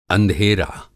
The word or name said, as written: अंधेरा